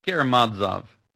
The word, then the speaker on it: Karamazov: Karamazov. – Karamazov. That's the correct pronunciation. Let's go with that. – The accent is almost always on the next to last syllable in these Russian names.